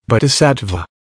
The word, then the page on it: bodhisattva: American English